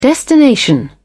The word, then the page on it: destination: British English